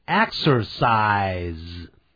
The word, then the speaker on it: exercise: 接着我们看这个现在分词是 exercising，本来它的动词是 exercise， E-X-E-R-C-I-S-E，那个注意到我的这个词尾念的时候念成 z 的音， exercise，当然不需要那么强调 exercise，这样就好了。